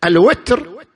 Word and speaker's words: الوتر: الوتر تنطق بالكسر وتنطق بالفتح كما في القران الكريم